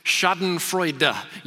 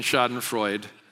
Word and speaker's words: Schadenfreude: Now, Webb Younts, who lived in Germany for a while after the early service, said it's pronounced Schadenfreude. You need to uh, add the uh at the end to pronounce it correctly. But I'm going to stick with my misinformed American pronunciation, Schadenfreude, nonetheless.